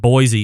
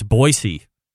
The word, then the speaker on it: Boise: It's not Boise, it's Boise. Like it's spelled.